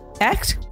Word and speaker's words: echt: Am I pronouncing it right, TJ? Echt? – Yes, you are.